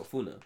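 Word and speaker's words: FUNAI: I guess what you could say is damn near poaching and just illegal activity on their lands was FUMA. I did say that correctly, didn't I? No, FUNA. But yes, FUNA.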